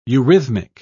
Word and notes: eurythmic: British English

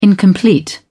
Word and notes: incomplete: British English

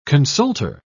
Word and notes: consulter: British English

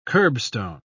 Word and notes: kerbstone: British English

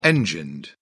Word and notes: engined: British English

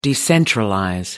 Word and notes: decentralize: American English

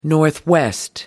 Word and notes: northwest: American English

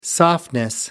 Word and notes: softness: British English

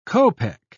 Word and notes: kopec: British English

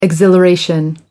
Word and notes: exhilaration: American English

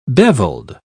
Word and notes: beveled: British English

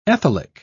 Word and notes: ethylic: British English

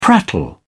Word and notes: prattle: British English

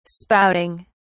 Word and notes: spouting: British English

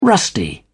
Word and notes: rusty: British English

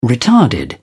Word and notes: retarded: British English

